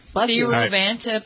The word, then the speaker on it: Thiruvananthapuram: T-H-I-R-U-V-A-N-A-N-T-H-A-P-U-R-A-M. – Wait, no, you're going too fast. T-I-R-U-V-A-N. – T-H-I-R. – Here. – U-V-A-N.